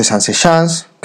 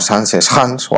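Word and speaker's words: Zaanse Schans: Pero también nos hablaremos de los alrededores, o lo que nosotros llamamos side trips, como ir a visitar los molinos de Sanseschans, que ellos lo pronuncian muy raro, como Sanseschans o algo así,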